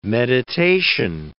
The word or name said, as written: meditation